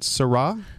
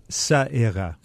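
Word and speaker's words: Ça Ira: Is it Sarah? – Sa-era. – Okay.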